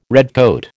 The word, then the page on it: redcoat: American English